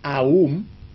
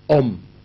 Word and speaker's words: Om: Hay la pronunciación Aum, A-U-M, y la pronunciación Om.